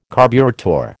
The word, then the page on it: carburettor: American English